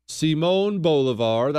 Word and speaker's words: Bolívar: Simón Bolívar, that's like the second or third time I've said his name right, Chris.